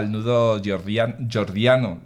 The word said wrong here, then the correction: Gordiano: ¿lo he dicho bien? Jordiano. – Gordiano. Sí, Gordiano, eso.